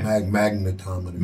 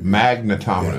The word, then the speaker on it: magnetometers: You know, take down the megatrometers." I think that's, I'm saying that right. – Magnetometers. – Magnetometers. Yes. Thank you, big bro.